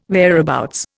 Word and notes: whereabouts: Indian

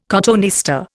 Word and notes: cotoneaster: Indian

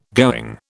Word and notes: going: British English

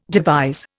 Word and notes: devise: Indian

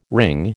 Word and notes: wring: American English

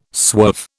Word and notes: swerve: British English

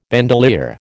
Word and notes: bandolier: American English

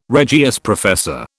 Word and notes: Regius professor: British English

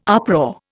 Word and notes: uproar: Indian

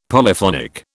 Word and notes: Polyphonic: British English

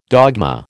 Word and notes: dogma: American English

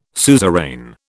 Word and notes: suzerain: British English

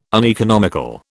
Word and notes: uneconomical: British English

